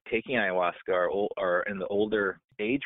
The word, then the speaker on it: Ayahuasca: So Ayahuasca. A-Y-A-H-U-A-S-E-A. Ayahuasca.